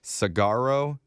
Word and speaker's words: Saguaro: The official flower, that's the, I can't pronounce this cactus, Saguaro. – Good job, bro.